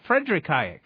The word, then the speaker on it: Friedrich Hayek: Friedrich Hayek.